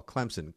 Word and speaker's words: Clemson: Clemson, great area. There's no P in there, Andy, but definitely pronounced as well.